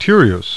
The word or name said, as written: Tereus